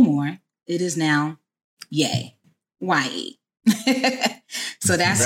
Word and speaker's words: Ye: Is it Ye or Ye? – Ye.